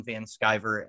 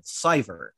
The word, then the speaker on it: Van Sciver: I was corrected by Ethan Van Sciver at a convention because I said Sciver and did not pronounce the C.